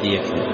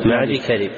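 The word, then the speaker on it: معديكرب: ليس معدي يكذب، مقدام بن معدي كذب، ابن معدي كذب، تنطق تكتب موصولة وتنطق مفصولة، ليس معدي يكذب إنما معدي كذب.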